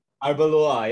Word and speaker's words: Arbeloa: where Luis Nani held a high boot against Alvaro Arbiola. I can't really remember how to pronounce his name. Arbeloa, yeah, sorry.